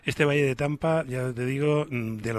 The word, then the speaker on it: Danba: se escribe Damba, pero se pronuncia Tampa,